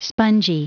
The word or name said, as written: spongy